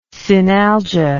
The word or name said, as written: synalgia